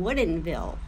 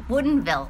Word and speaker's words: Woodinville: Well, first off, we're in Woodinville, Washington. – Woodinville.